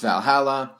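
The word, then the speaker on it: VA-11 Hall-A: Well, okay, technically the game is called VA-11 Hall A, but it's pronounced Valhalla.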